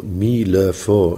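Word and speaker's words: Mi Le Fo (弥勒佛): So that what they call Mi Le Fo in Chinese language, in Mandarin, Mi Le Fo is the pronunciation for Maitreya Buddha.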